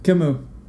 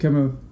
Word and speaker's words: Camus: Camus. Yep, Camus. – C-A-M-U-S.